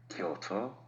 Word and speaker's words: Kyoto: Remember, Kyoto and Tokyo. Two syllables, not three.